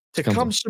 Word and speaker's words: Tecumseh: Tecumseh? – Yeah. – Tecumseh, Michigan.